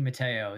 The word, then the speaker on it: Matteo: Everywhere is 6ABC Mateo.